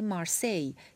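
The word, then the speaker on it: La Marseillaise: مثل ترانه مارسی یا به تلفظ فرانسوی لمخسیز اثر کلود جوزف خوژه دولیلا کنوانسیون ملی فرانسه این سرود به عنوان اولین سرود ملی از تاریخ 1795 به رسمیت شناخته.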